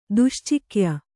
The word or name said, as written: ದುಶ್ಚಿಕ್ಯ